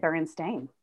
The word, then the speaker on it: Berenstain: It's spelled Stain, S-T-A-I-N. That's how it always was spelled growing up. I've always said Berenstain.